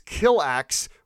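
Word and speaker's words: Kilaak: And it's basically the same stuff, as she's just saying, oh yeah, so the aliens are called the Killax. They don't pronounce it that way in Japanese, but just pronouncing it in English, it's Killax, which, I mean, right, seems a little on the nose, I don't know.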